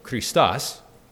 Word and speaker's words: Christos: Christ is the transliteration of the Greek word Christos. Actually, I said that wrong. Christos, meaning anointed one.